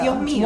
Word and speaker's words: Dios mío: If you said it properly, you it's not a plural. – Yes, my, it's true. Yes. – Oh my God. – Mio. – Dios mio.